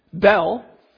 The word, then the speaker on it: Bel: Bel, which is another pronunciation for Baal, who was the sun god who, in their thinking, was the source of all energy and power.